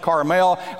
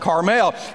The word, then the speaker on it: Carmel: I used to always call that Mount Carmel. But since I've gone to Israel a few times, and my guide who was raised in Israel, he always pronounces it Carmel. I figure he knows more than what I know of how to pronounce it. So I try to remember to pronounce it Carmel.